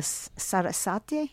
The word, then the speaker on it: Sarasate: and I understand the Sarasate.